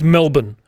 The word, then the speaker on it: Melbourne: In Australia, remember, we shorten everything. – Yeah. – Mm-hmm. So Melbourne is Melbourne.